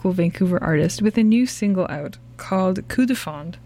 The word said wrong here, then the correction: Coup de Foudre: Did I say that right? – Coup de Foudre. – Coup de Foudre. – So close.